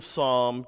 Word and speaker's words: Psalm: If you have your Bibles, or you've got your phones with the Bible app, the Book of Psalm, chapter 74, is where we're going. Now, as you can see, Psalm is spelled with a P at the start.